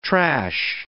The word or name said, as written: trash